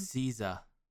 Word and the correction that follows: SZA: I think if I'm reading this phonetic pronunciation properly. – Really? – It's capital SIZ-UH. That'd be SZA or SZA? – SZA. – SZA. – SZA. – Okay, that makes a little more sense. SZA.